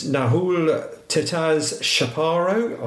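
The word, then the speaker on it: Nahuel Tetaz Chaparro: We have a short-term international signing, and that is Nahul Tetaz Shaparo. I probably haven't pronounced that right, but that's... – You did well. You did well, mate.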